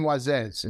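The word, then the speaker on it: Moisés: Am I pronouncing that correctly? – Aaron and Moises in Spanish.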